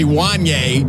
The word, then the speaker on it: Wanya: Okay, first of all, you only named three people, and Wayne's name is actually Wanya. – Wanya. – You still didn't say it right. It's Wanya, not Wanya.